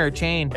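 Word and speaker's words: Achane: Is it a cane or a chain? – A chain says EJ Will McClay. pronounced A-Chain, I believe.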